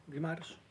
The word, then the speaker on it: Guimarães: Guimarães.